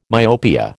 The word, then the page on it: myopia: American English